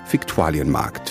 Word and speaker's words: Viktualienmarkt: den Markt 1807 an seine jetzige Stelle und nannte ihn latinisiert Viktualienmarkt für Lebensmittel, weil man das damals schick fand. Geschrieben mit V, ausgesprochen aber mit F.